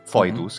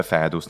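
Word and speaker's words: foedus: Però questo è strano, perché come mai abbiamo ritenuto questo au, ma non ai, ai come e foidus, che è brutto, si pronuncia fedus nella pronuncia ecclesiastica, ma come mai quando au diventò oro?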